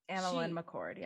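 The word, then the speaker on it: AnnaLynne McCord: And I mean, Annalyn McCord, that's how you say her name, right? – Annalyn McCord, yeah.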